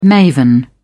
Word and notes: maven: British English

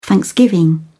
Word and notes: thanksgiving: British English

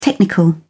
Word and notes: technical: British English

